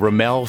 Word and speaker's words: Ramael: Ladies and gentlemen, Ramel Scully.